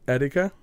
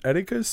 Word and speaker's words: Etika: It's a former YouTuber, E-T-I-K-A. – Etika? – Etika? – Maybe. – Okay. – Yeah. – I don't know. So Etika, he said, on the subject of death, Etika's suicide last year had me legitimately mourning.